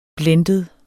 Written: blended

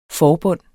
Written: forbund

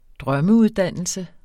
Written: drømmeuddannelse

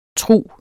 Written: trug

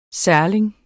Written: særling

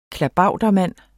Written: klabautermand

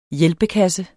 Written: hjælpekasse

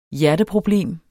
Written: hjerteproblem

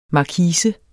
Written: markise

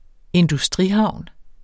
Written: industrihavn

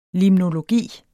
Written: limnologi

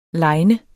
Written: liner

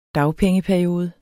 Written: dagpengeperiode

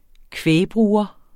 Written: kvægbruger

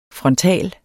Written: frontal

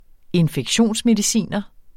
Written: JORDSMONNET

